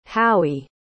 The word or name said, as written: howie